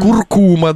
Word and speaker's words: куркума: Мы выяснили, что ударение в этом, в этой приправке должна быть куркума, да.